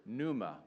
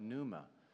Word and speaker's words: pneuma: The word Spirit and the word wind in Greek, it's the same root word, pneuma, with a silent P if you want to look it up, pneuma.